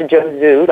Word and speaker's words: JOZU: We pulled a bunch of women at the beginning of our research about JOZU, and it's pronounced JOZU, like Joe, the man's name, and zoo, like where animals are.